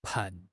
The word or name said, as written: 畔